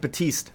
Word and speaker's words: Batiste: Number one was We Are by John, how do you say that? Baptiste? – That's like Batiste.